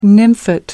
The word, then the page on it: nymphet: American English